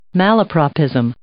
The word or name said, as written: malapropism